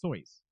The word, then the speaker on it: Seuss: Or you would think, but that's the thing is that Seuss is spelled S-U-E-S-S, but it's supposed to be pronounced Soyce. – It's S-E-U-S-S. – S-E-U.